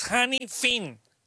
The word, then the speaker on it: Hannifin: Ah, caray, Hannifin. Yo creo que es Hannifin. Parker Hannifin Corporation. Ahí está. Ya lo pronuncié bien, Mario.